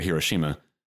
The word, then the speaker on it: Hiroshima: But then reading Hiroshima, I was like, or Hiroshima is, you know, how you would pronounce it more accurately.